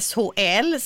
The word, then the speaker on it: SHL: Högsta ligan heter ju SHL. Ska det uttalas på svenska eller engelska? – Svenska funkar bra.